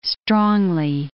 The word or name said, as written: strongly